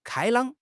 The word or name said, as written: 开朗